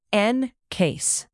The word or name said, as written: encase